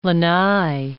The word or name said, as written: lanai